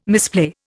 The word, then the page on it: misplay: Indian